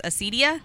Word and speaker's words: acedia: You know, so sloth, also known as acedia. Am I pronouncing that right? – I believe so.